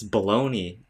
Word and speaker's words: bologna: And then my friend looked over at me like I was stupid, and he's like, bro, it's baloney. I'm like, who spells baloney like that? Like, you'd expect it to be spelled like B-A-L-O-N-E-Y. – Yeah, exactly. Who spelled, why is it bologna?